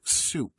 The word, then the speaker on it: Suep: I am your host, as always, opening up the program with a band out of Southeast London called Soup. It is spelled S-U-E-P, now featuring George Nichols.